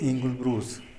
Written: ingulbrus

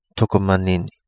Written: Togo Manini

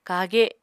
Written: kage

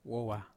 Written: vowa

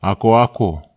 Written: akoako

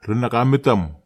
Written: renakametam

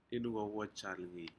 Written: incowaj algei

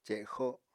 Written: cexo

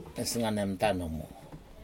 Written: nesganemtan numu

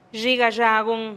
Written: xhi gaxagun

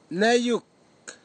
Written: neiuk

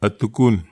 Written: atukun

